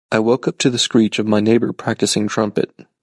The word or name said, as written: practicing